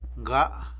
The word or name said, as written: nga